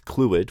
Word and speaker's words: Clwyd: One of our most avid listeners, my mum, was aghast that I didn't know about the Vale of Clwyd, which I've been assured is pronounced Clwyd, because it's exactly where she was born. She's very disappointed and shocked and appalled that now people all over the world will hear of my ignorance of her birthplace, but I apologize.